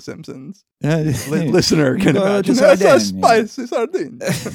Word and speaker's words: Sardinia: Sardinia.